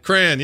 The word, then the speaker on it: crayon: Crayon, yeah. My daughter says crayon. – I am a distinct 2-syllable on the word crayon. – It is correct what you're doing. She's wrong. Yeah. You are right.